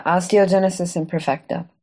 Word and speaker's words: osteogenesis imperfecta: Yeah, osteogenesis imperfecta.